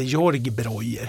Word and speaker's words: Georg Breuer: Eh, den heter George Breuer. Eller Georg Breuer heter den egentligen, det är lite, så ska det väl uttalas. Och det är eh, en eh, vinmakare som heter Teresa Breuer, som var hennes farfar som startade mm.